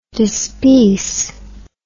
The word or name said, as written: dispeace